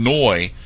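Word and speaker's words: Ngoy: His last name is N-G-O-Y. I assume that's one of those letters. I guess the G is silent. Fiston Noy.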